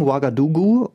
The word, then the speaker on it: Ouagadougou: ausgesprochen Ouagadougou und äh, man schreibt es, wie man es spricht eigentlich. – Ja, mit dreimal OU.